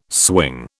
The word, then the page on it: swing: British English